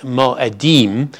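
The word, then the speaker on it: Mo'edim (מועדים): but in English we would pronounce it Mo'edim, and what it literally means is appointed times.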